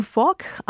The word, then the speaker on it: FOC: She called the restaurant Fok. I spell it as F-O-C.